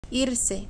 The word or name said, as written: irse